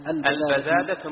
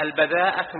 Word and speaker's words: البذاذة: البذاذة من الإيمان، بعض الناس ينطقها هكذا البذاءة من الإيمان، نقول معصي الإيمان ما في بذاءة، إنما البذاءة في رأسك ولا في لسانك، ليس في الإيمان بذاءة.